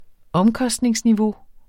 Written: OMKOSTNINGSNIVEAU

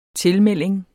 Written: tilmelding

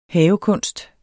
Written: havekunst

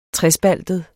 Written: trespaltet

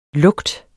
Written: lukt

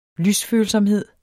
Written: lysfølsomhed

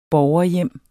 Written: borgerhjem

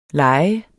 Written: lejer

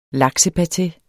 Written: LAKSEPATE

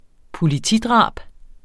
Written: politidrab

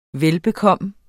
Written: velbekomme

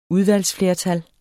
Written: udvalgsflertal

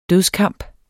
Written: dødskamp

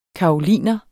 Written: karoliner

